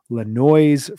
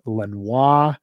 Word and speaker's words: Le Noise: Produced one of my favorite Neil Young records, which is called Le Noise from 2010, which can also be pronounced Lenoir.